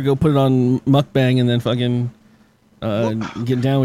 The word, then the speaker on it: mukbang: Don't let white people pronounce Korean words, please. – Yo, somebody put that in there as a title. Fucking don't fucking spell it. Don't spell it the way it sounds. It's mukbang.